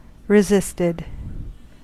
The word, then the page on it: resisted: American English